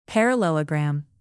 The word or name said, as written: parallelogram